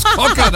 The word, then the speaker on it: Cocker: Achtet bitte genau auf den Interpreten, wie der ausgesprochen wird. – Ja, bitteschön. In der ewigen Top 10 der Sommerhits Joe Cocker. – Cocker, sag ich schon. – So, Cocker natürlich.